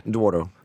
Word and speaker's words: Douro: Douro.